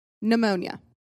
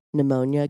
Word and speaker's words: pneumonia: Pneumonia. – Okay, so Tennessee. I'm going to think that you want me to guess pneumonia because that silent P could be tricky.